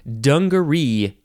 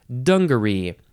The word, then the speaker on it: dungaree: Dungaree is next, and you can emphasize the first syllable or the last syllable. Dungaree or dungaree.